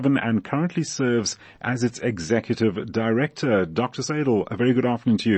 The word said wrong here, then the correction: Saidel: And let me just say it's Saidel before you say it wrong again. – It's, it's Saidel. Sorry, Saidel.